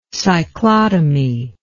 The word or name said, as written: cyclotomy